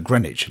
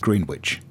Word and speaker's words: Greenwich: Yeah, but that is pronounced Greenwich, but the one in New England is pronounced Greenwich. It's different emphases, different pronunciations, and it sort of makes sense that as, you know, different people get hold of a word, that it evolves.